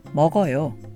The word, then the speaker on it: 먹어요: Add yo to this and we have 먹어요, which is pronounced 먹어요.